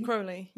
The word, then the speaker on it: Crowley: Crowley, yeah, it rhymes. – Crowley. – His poem about this, to clarify, is people call me Crowley because they wish to treat me foully, but actually it's Crowley because I am holy.